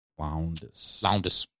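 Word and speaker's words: Lowndes: Loundis. Is that how you say it? – Mm-hmm. – Yeah. – Let me see. – Loundis. – Yeah, Lyle Loundis.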